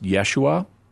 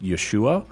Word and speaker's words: Yeshua: You commonly hear it pronounced Yeshua. You'll also hear it pronounced Yeshua. And in modern Aramaic, you'll hear it pronounced Ishoa. So there's some variation even within a language in how a name gets pronounced.